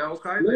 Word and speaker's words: Al-Qaeda: Agora acho que eu falei a pronúncia errada de todo, né? – Um Não, o Caído. Tá é Al-Qaeda.